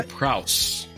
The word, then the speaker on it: prowess: This is the part where we shine with our elocutionary prowls. Did I say that right? – No. – Prowess? – Elocutionary prowess.